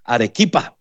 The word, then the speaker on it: Arequipa: Arequipa, creo que se llama. Perdón, Rita, se lo dije mal. Arequipa, mi hermana, la cafetera Rita, allá en el Perú, ya conectados.